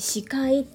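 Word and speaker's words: 四海: 4 つの海という漢字で書いて視界って読む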